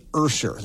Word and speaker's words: Usher: I should have known it was Usher, or some might say Ursher. That's another alternate pronunciation.